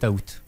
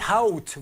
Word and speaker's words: août: Et vous, ça date de quand Alors l'achat a été fait le 7 août. – Le set out, vous le prononcez comme ça.